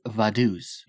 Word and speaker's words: Vaduz: The capital is Vaduz. V-A-D-U-Z. They do speak German, so it's probably not pronounced that way.